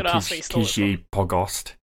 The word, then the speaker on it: Kizhi Pogost: Kizhi Pogost.